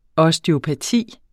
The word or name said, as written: osteopati